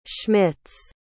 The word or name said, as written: Schmitz